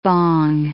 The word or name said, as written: bong